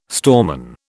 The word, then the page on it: storeman: British English